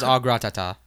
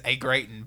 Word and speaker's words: au gratin: See, I pronounced it the other day as a gratin, but I know for a fact that's not how you pronounce it.